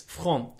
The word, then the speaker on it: front: No, it's quite easy actually. It's front, but it's actually it's spelt like the word front.